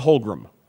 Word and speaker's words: Holmgren: Right, it was always Holmgren. – Holmgren, Wanstead, that's right. – Wanstead and Doug Buffon both routinely referred to him as Holmgren, even though it's Holmgren.